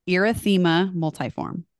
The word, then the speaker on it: erythema multiforme: So I'm going to see if I can say it right. Erythema multiforme. Yeah. – Yep. Okay. That's right.